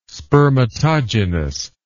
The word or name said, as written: spermatogenous